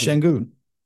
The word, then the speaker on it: Şengün: Is that, how do you pronounce his name again? I want to make it pronounce Şengün. – Şengün, yes. Okay, I just want to make sure I'm pronouncing it.